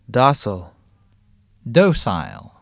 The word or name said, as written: docile